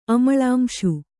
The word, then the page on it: ಅಮಳಾಂಶು: kannada